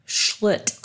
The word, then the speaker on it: Schlitt: Try to say her name. Schlitt. I like easy. it. Schlitz Malt Liquor.